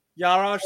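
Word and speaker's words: Juraj: Yarov Slavkovsky. I'm going to butcher that. – Yarosh. – What's that? – Yarosh Slavkovsky. – Yaroj Slavkovsky. Mm-hmm.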